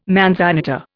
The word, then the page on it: manzanita: Indian